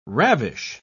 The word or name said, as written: ravish